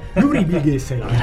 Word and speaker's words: Nuri Bilge Ceylan: La pronuncia turca è proprio questa. Nuri Bilge Ceylan.